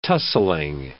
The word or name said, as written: tussling